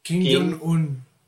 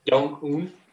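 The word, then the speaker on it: Kim Jong-un: Kim, Jong-un. – Jong-un.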